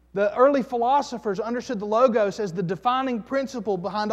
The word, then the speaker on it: logos: That Greek word is a word called logos, or L-O-G-O-S, logos, or you might pronounce it logos.